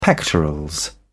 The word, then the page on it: pectorals: British English